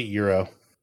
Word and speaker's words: gyro: I'm impressed that you pronounce it euro. – What did I say? It's a euro. – You said it the right way.